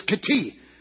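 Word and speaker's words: Kiti (כתים): Now the Hebrew word to pronounce this is Kiti,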